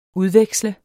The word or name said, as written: udveksle